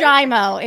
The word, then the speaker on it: Giaimo: It's Italian, right? It's Giaimo. It's an Italian diphthong.